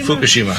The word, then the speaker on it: Fukushima: Fukushima.